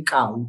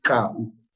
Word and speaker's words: Kau: So if you were from here or non-white, you would say Cow, David Cow, which is K-A-U.